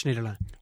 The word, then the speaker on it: Schneiderlin: Philippe Auclair has told us that it's Schneiderlin.